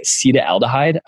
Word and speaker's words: acetaldehyde: and I always mispronounce it. It's like acetaldehyde, I believe is how you pronounce it. – Yeah, you're right.